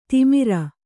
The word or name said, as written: ತಿಮಿರ